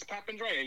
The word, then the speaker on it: Papandrea: It's Papandrea.